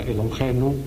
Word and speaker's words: Elohenu: Por eso yo he puesto con este sonido gutural, con una H espirada, Elohenu.